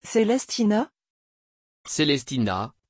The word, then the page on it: celestina: French